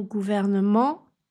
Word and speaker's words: gouvernement: Je pense aux mots gouvernement et government ou encore à parlement et parliament. En français, gouvernement compte plus de syllabes qu'en anglais, government. Gouvernement, 4 syllabes. Government, 3 syllabes.